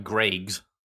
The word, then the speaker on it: Greggs: And famously, when you have a shop or a bakery, as Greg's is, or can we just, for the American listeners, pronounce it how they'd expect it to be pronounced. – Greggs. – No, G-R-Eggs.